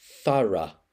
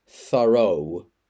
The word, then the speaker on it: thorough: Next, another noun, axe, A-X-E, axe. And finally, an adjective, thorough. And let me spell that. T-H-O-R-O-U-G-H, thorough. In American, the pronunciation is thorough. But in British English, we say thorough.